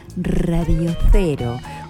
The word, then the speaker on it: Radio Cero: Lo digo así, Radio Cero. Porque nuestro cero se escribe con Z